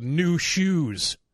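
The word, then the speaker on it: Nu Shooz: It's by a band called New Shoes. But it's N-U-S-H-O-O-S or something. They spell it weird.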